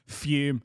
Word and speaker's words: Fum: But it is nothing compared to my absolute favourite sponsor of ours, Fume, spelled F-U-M, but it's pronounced as if there's an E on the end.